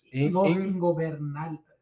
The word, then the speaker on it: Ingobernables: Ingobernables? – There you go. – Ingobernables. – Yeah, versus the House of Black for the AEW World Trios titles. – Now it's the ungovernables. Los Ingobernables.